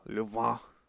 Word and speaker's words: Louvain: He was then sent to the University of Louvain in Belgium. They all said it like that in all the Louvain. It's spelled Louvain, but uh, you know it's in Belgium, so yeah, Louvain, where he got his PhD in archaeology, Oriental history, and Semitic languages.